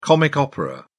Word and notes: comic opera: British English

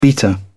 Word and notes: beater: British English

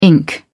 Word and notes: ink: British English

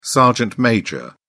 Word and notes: sergeant major: British English

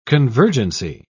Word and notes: convergency: British English

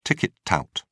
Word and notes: ticket tout: British English

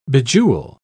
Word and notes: bejewel: British English

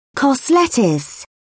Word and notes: cos lettuce: British English

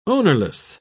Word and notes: ownerless: British English

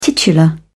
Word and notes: titular: British English

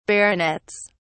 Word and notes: baronets: British English

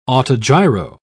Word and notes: autogyro: British English

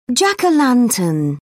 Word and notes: jack-o-lantern: British English

